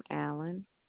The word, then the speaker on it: Allen: Last name, Allen, A-L-L-E-N.